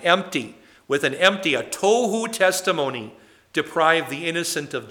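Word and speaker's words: tohu (תהו): The first one is pronounced tohu. Tohu. That's not some bizarre food that you get at a health store. Okay, but it's pronounced similarly, but it is tohu.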